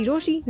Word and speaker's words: Rochie: मेरा नाम है मिर्ची रोशी,